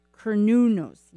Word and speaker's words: Cernunnos: what was that the Cornunos? Cornu- Cernunnos.